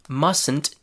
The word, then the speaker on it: mustn't: The contraction mustn't is written with a letter T, but the T is not pronounced. So listen again. Mustn't. There's no T there. It's not mustn't. It's mustn't.